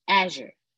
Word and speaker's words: Azure: You said it right. Azure. Thank you, because I always say it wrong.